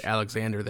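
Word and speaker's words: Alexandre: Just say Alexander there, even though it's spelled with the D-R-E at the end.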